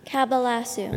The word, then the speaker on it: cabalasu: Cabalasu. – Oh, no, 2,900? – Here comes the spelling. – I don't remember. – She's going to say the word one more time if I know her. – Cabalasu.